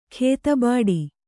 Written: ಖೇತಬಾಡಿ

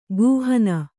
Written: ಕುಹನ

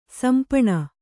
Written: ಸಿಂಪಣಿ

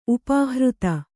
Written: ಉಪಹೃತ

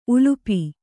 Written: ಉಲುಪಿ

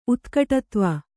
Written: ಉತ್ಕಟತ್ವ

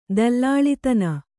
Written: ದಲ್ಲಾಳಿತನ

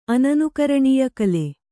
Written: ಅನನುಕರಣೀಯಕಲೆ

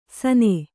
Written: ಸನೆ

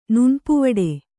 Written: ನುಣ್ಪುವಡೆ

